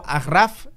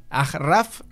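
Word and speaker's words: Achraf: Y luego Agraf, Agraf eh, que así es como se pronuncia, por si tenéis dudas.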